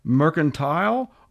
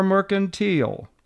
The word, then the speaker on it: mercantile: The one question people always ask, is it pronounced mercantile or mercantile? Our answer, either way is perfectly acceptable.